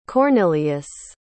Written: Cornelious

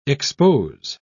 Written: expose

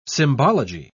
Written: symbology

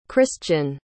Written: cristian

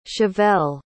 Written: chavelle

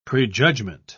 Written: prejudgment

